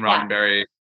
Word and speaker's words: Roddenberry: So like Gene Roddenberry, did I say his name right? – You did.